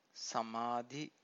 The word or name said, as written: samadhi